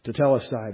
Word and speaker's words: tetelestai: This is this word here. T-E-T-E-L-E-S-T-A-I. Everybody see this little accent mark right here? It tells you how to pronounce the words. The accent is where the accent goes. The emphasis goes on the correct syllable. So, tetelestai. That's the way you say that word.